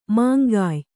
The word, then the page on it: ಮೇಂಗೆಯ್: Kannada